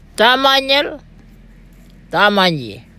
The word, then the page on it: thamanyel-thamanyji: Gija